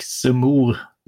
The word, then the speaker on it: Zemmour: det de, du har ju redan nämnt han eh, Erik Zemmour. Mm. Är det rätt uttal? Får jag bassning för uttalet? Nu? Ja, det är, bara, vem, vem är det är jättebra.